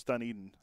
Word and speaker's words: Dunedin: No, it's Dunedin. That's what Dunedin? – That's all I've ever heard everyone say. – That's always, but that's not how it's spelled.